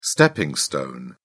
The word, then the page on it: stepping stone: British English